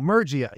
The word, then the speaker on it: Murgia: No, Murgia. He said it right.